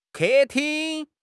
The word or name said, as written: ke4 ting1